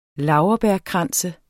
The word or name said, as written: laurbærkrans